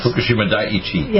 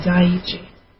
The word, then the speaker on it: Fukushima Daiichi: Near Fukushima Daiichi. – Yeah, Daiichi.